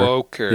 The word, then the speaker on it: woker: It rhymes with Joker. Rhymes with joke. If you're not asleep, you're woker.